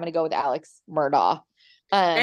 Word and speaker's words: Murdaugh: Um, but so I went in completely blind um, on the Netflix documentary, The Murdaugh Murders. And people pronounce it different. So it's either Murdaugh, Murdaugh, or Murdoch.